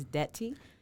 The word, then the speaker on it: Ndeti: My name is Chris Ndeti. The N is silent. – Oh, I didn't know that. I've been saying Ndeti this whole time.